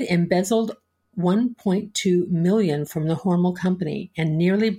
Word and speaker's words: Hormel: Hormel is how the family pronounces it.